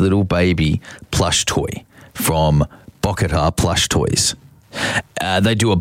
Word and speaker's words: Bocchetta: I'll spell it for you. It's BOCCHETTA. Bokatar. BOCCHETTA. Bokatar plush toys.